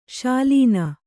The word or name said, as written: ಶಾಲೀನ